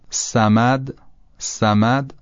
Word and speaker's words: صمد: اگر سین رو تلفظ کنیم و این رو یه مقدار غلیظش بکنیم صاد به دست میاد و همین راحتی. مثلا فرض کنید میگیم صمد صمد، حالا همین رو غلیظش میکنیم صامد صا صا به جای س، فتحش هم یه خورده به سمت بالا ببریم راحت تر تلفظ میشه صا صا س صا س صا.